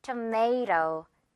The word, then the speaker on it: tomato: 자, 마지막 단어 역시 토마토라고 우리가 알고 있다 보니까 영어로도 약간 토마토라고 해야 되나 헷갈릴 수 있는데 A 발음 그래서 tomato, may 발음 여러분 처리해주면 됩니다.